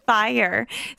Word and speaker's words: Fyre: They spell it, it's Fry Festival is how I try to say it. And it's because they spell it F-Y-R-E Festival. It's the, how would you say that? – Fyre. – Fyre. – Yeah. – That's how you would say it? – Fyre?